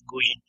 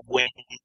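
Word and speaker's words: Nguyen: vietnamitas lo dicen como Gwen, pero hay algunas partes donde lo dicen como Guggen.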